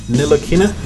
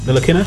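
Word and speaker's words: Ntilikina: T is silent. I think it's pronounced Nilakina. Nilakina.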